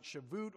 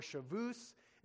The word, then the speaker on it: Shavuot: You can pronounce it Shavuot or Shavus.